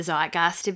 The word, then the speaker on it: zeitgeist: Do you think that's why we read the zeitgeist a bit? – Well, yeah. – Zeitgeist? – Zeitgeist? – What did I say? Zeitgeist. – I think you said zeitgeist. I think you said it correctly.